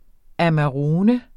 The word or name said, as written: amarone